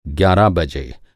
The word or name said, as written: ग्यारह